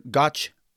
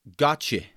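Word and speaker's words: Gøtzsche: Peter Gotch, Gotche, I think is how you say it, Gotche, yeah, uh, who's uh, from the Netherlands, is the co-founder of the reputed Cochrane Collaboration.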